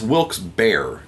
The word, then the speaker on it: Wilkes-Barre: It's pronounced Wilkes-Barre, Pennsylvania, of course it is.